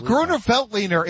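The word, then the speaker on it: Grüner Veltliner: And did I butcher the pronunciation of that Chardonnay, champagne, or whatever? Probably. – No, Gruner Veltliner. It's a white varietal usually found in Austria.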